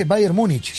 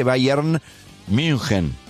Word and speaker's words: Bayern München: Sí, pero Bayern Múnich no es. Si es Bayer con Y, Bayern München. Si lo vas a pronunciar en alemán. O lo pronuncian en castellano o en alemán.